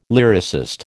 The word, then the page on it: lyricist: American English